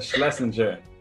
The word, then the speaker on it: Schlesinger: It's Schlesinger, Mac Schlesinger.